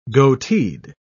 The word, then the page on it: goateed: British English